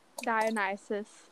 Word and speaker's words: Dionysus: Dionysus.